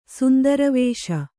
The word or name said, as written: ಸುಂದರವೇಷ